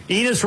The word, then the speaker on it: Enes: Enes rhymes with penis.